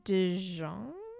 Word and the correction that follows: DeJonge: I feel like I just horribly pronounced like, her last name. It's DeJonge. She's in The Society on Netflix.